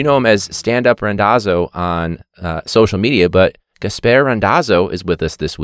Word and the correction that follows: Gaspare: So it's actually Gaspare. – Oh, Gaspare. Like, I'm sorry. – No, it's everybody's butchered it my whole entire life. It's like Casper the Friendly Ghost with a G, but I've been called like every variation of that, those letters.